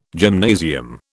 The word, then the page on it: gymnasium: British English